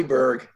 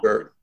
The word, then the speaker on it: Burg: You can call me Burg. You can call me Burg. Just spell it right and it's okay. – I will. B-U-R-G.